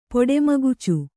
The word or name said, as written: ಪೊಡೆಮಗುಚು